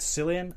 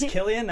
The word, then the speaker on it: Cillian: It's pronounced Cillian, not Cillian. I never. – You say you agree? – I agree with you. Yeah. I do. I do agree. – The word's out. It is Cillian.